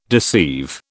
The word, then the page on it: deceive: British English